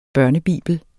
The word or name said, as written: børnebibel